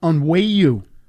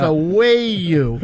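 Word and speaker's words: Onwenu: And on, I guess it's pronounced Onwayu. That's how it's pronounced. Onwayu. Yeah. – That's what I heard this week. It's pronounced Onwayu.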